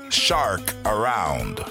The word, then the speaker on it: Chacarrón: Chacarrón, su pronunciación correcta es en inglés shark around, lo cual es tiburón dando vueltas.